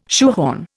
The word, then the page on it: shoehorn: Indian